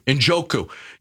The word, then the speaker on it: Njoku: You know, Njoku, is that how you say his name? – Njoku.